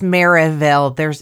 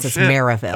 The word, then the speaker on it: Merrillville: Reverend Michael had been the priest at St. Stephen Martyr Parish in Merrillville for more than 10 years, but had never received a request like this one. – It's Merrillville. – It's a region thing. You don't pronounce the L's. Merrillville.